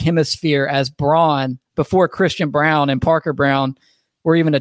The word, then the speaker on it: Braun: B-R-A-U-N. Oh, so Christian Braun. No, Christian Brown. There is no scenario that doesn't spell Brown.